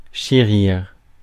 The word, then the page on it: chérir: France; /ʃe.ʁiʁ/